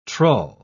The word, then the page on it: trull: British English